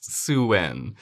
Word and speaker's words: Samhain: Sam Hain, yeah, but pronounced Suwen.